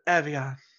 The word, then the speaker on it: Evian: In America, we pronounce it Evian.